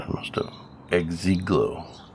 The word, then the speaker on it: Exegol: Yeah, it's Exegol. That's how it's pronounced.